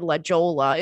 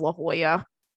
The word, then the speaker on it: La Jolla: I kind of have anxiety about pronouncing places because of that time that I said La Jolla instead of La Jolla.